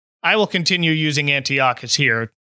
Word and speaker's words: Antiochus: And of course, Anton is standing in for, well, they pronounce his name Antiochus in this special. – Yeah, I've always heard Antiochus.